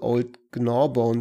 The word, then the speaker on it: Old Gnawbone: also Old Gnawbone zum Beispiel.